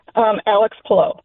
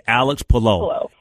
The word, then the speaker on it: Palou: For the record, how do you pronounce the winner's name? – Alex Palou. – Palou. Okay, Alex Palou.